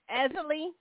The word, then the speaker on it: Easley: Easley.